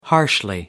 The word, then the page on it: harshly: British English